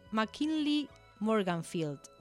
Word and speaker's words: McKinley Morganfield: Otra persona que nació en abril, pero de 1913, fue Muddy Waters, que en realidad se llama McKinley Morganfield. Lo pronuncié. – Muy bien.